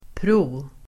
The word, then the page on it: pro-: [²pro:]